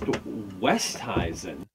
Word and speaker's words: Oosthuizen: Oosthuizen said once in an interview that it's supposed to be pronounced Westhuizen.